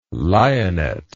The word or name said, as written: lionet